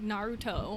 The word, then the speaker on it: Naruto: Excuse me, it's pronounced Naruto.